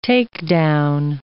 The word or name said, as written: takedown